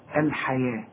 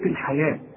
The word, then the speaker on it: الحياة: اشتروا الحياة، الحياة مكتوبة بالواو ولكن تنطق بالألف، ورسم لك ألف فوق الواو بيقول لك انطقها ألف كاملة، الحياة، لكن إذا كتبتها تكتبها بالواو. إنما إذا كتبت الحياة في كراستك أو في أي كلمة اكتبها بالياء، حياة بالألف بقى.